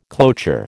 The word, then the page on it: cloture: American English